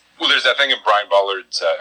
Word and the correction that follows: Bolland: What, there's an N. – Bolland.